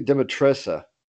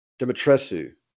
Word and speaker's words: Dimitrescu: I'm gonna butcher her name, Lady Dimitressa. Dimitrescu.